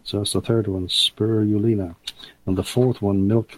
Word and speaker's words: spirulina: The fourth one is spirulina. I'm pronouncing S-P-I-R-U-L-I-N-A.